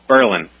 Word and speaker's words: Berlin: It's not Berlin, it's Berlin.